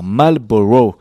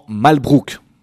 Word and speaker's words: Marlborough: Et John Churchill fut le premier duc de Marlborough, une ville d'Angleterre, Marlborough, que les Français, déjà très doués dans la prononciation anglophone, comme moi, ont transgressé en Malbrook. Tellement plus simple.